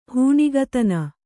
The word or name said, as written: ಹೆಣ್ಣಿಗತನ